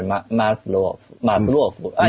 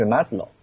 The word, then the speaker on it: Maslow: 啊 okay 他应该是俄国人嘛，所以是按他的俄文发音应该是马斯洛夫，按英文就是 Maslow。